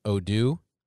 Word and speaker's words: Odoo: So the name of the program you use, and I might butcher this, is Odoo? – That is correct.